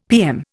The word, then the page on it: PM: Indian